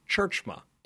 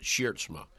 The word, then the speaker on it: Tjeerdsma: First things first. The last name pronunciation of the Bearcats head coach, spelled T-J-E-E-R-D-S-M-A, is Churchma. – It's a Dutch name. The Dutch, we were in Holland a couple of years ago, and the Dutch don't have any problem with it. They pronounce it Schiertsma, but it has been Americanized.